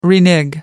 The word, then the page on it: renege: American English